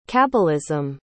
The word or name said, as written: kabbalism